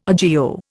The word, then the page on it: agio: Indian